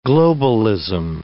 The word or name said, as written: globalism